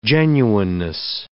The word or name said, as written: genuineness